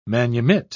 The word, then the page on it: manumit: British English